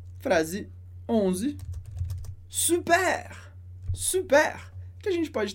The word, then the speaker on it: super: A gente usa muito em francês, super, pronunciando R, porque é uma palavra que vem do inglês, super, tipo, que legal, ótimo.